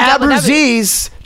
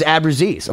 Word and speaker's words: Abruzzese: Abruzzese, just so you know. It took me 25 years. He's like, dude, my name's Abruzzese. I'm like, oh, okay.